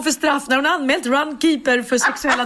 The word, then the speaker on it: Runkeeper: Hon tog fram sin mobil och visade och då insåg jag att Runkepär uttalas inte Runkepär utan Runkeeper.